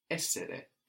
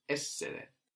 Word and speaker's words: essere: To be in Italian, so the to form to be is essere, essere. – Essere. – That's spelled E-S-S-E-R-E, essere. – Essere. – So we have the accent there free back from the end, or at the beginning, no? In this case, essere. Like in vendere, to sell, or conoscere, to know.